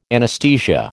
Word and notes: anesthesia: American English

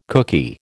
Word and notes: cookie: American English

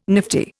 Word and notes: nifty: Indian

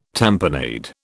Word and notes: tamponade: British English

